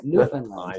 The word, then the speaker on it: Newfoundland: It's Newfoundland. – Yeah, well, whatever. I'm from Boston and trying to be politically correct. – Okay. First of all, I'm not trying to be politically correct. That's how you say it, Newfoundland.